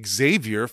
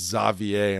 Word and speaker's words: Xavier: And then when I took French class in seventh grade and you had to pick a name, I picked Xavier for Xavier McDaniel. – Xavier McDaniel. – And the French teacher was like, it's actually pronounced Xavier.